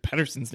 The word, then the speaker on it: Pettersson: And him and Conor Garland, along with Elias Pettersson down the middle, well, almost butchered Pettersson's name,